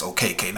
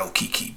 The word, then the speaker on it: Okeke: Chuma Okeke. That's how they pronounce it on the broadcast. OKK, not Okiki.